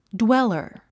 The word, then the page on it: dweller: dwélə r; ドゥエラァ